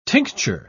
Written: tincture

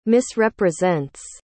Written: misrepresents

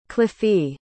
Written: cliffie

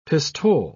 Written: pistole

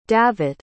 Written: davet

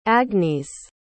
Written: agnese

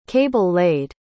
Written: cable-laid

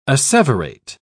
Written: asseverate

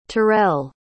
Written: terrell

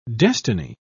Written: destiny

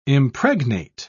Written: impregnate

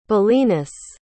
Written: belenus

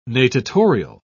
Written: natatorial

